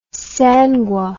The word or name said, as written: sangui